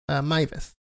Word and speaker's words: Meiwes: El caso del alemán Armin Meiwes, que se pronuncia Meiwes. Armin Meiwes.